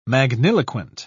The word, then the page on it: magniloquent: British English